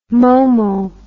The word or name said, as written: momo